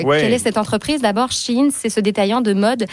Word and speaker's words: Shein: D'ailleurs, comment on prononce? C'est Shein ou c'est Shein? – Moi, je dis Shein. Shein mais aussi, je sais que voilà. J'entends des fois les deux.